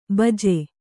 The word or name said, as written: ಬಿಜ್ಜೆ